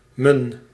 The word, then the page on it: m'n: /mən/